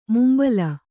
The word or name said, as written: ಮುಂಬಲಿ